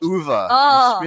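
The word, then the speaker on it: Uwe: And oh boy, I wonder if U-Bowl will direct it. – Uva. Mispronounced his name.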